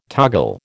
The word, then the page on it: toggle: American English